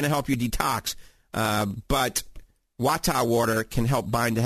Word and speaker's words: Wattahh: That's Wata water. It's spelled W-A-T-T-A-H-H. Wata.